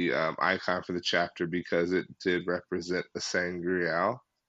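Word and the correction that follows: sa'angreal: Saying Angreal, because I could never say it right. Saw Angreal. Thank you.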